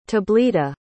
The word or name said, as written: tablita